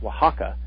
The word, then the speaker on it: Oaxaca: Now, you have some new stuff, the Osaka Adventure in Mexico. Tell us a little bit about that. – It's pronounced Oaxaca.